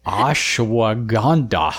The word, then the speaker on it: Ashwagandha: And what is the one that I'm not going to be able to pronounce? Ashwagandha? – Ashwagandha. – Oh, well, I was closer than I thought.